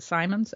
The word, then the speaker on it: Simmons: It's S-I-M-M-O-N-S, and he pronounces it Simons?